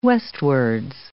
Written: westwards

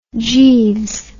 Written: jeeves

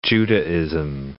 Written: Judaism

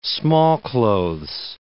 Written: smallclothes

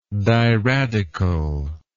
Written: diradical

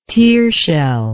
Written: tearshell

